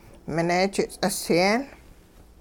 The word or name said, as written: mineejit ts'isyeen